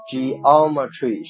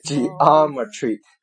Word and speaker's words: geometry: 第一个单词 geography 地理学，第二个 geology 地质学，第三个有两个读音，一个叫做 geometry 是英音，美音呢读成 geometry 叫做几何学。